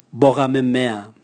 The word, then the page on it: boga memea: Raga